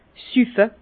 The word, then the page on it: süffe: Bas-Rhin; Herrlisheim